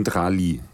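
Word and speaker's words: Tralee: Du bist ja geboren in Drali. Ja. Ich hoffe, das spricht man richtig aus, oder? Ja, Chile.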